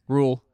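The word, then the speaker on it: Rhule: Rule.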